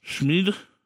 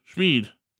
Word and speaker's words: Schmid: Schmid. – Schmid? – Schmid. Schmid.